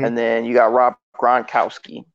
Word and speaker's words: Gronkowski: Who's their freaking – Rob Gronkowski.